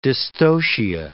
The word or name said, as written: dystocia